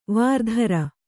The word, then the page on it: ವರುತ್ರ: varutra